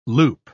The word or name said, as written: loop